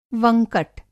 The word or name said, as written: वंकट